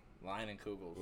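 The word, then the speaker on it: Leinenkugel's: These like little linen halls. Is that how you say it? – Lion and Kugels. – Lion and Kugels. Oh, I thought that was an H.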